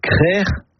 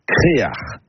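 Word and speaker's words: Krec'h: Et la bonne production est crère ou créar. Créar, c'est une prononciation plus du Nord Finistère, mais on la trouve parfois ailleurs.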